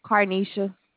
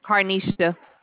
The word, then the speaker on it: Karnesha: Carnesha. – Thank you. Carnation? – Carnesha. – How do you spell that? C-A-R-N-A-T? – K-A-R-N-E-S-H-A. – K-A-R-N-E-H-A-N.